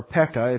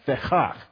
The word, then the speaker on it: Pekah: and Fechak, or Pekah, if you read it in your English, Pekah is how the Bible, the audio Bible guy will pronounce it, Pekah, it's Fechak.